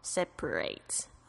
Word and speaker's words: separate: 那再来就是有一个是 separate 后面这个 R-A-T-E 会变成这 A 的这个发音的时候呢，其实它是把什么东西分开来 separate， OK、比如说你要把这一组呢分成两个地方，你就可以说 I need to separate this team into two teams， OK。